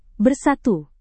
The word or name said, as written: bersatu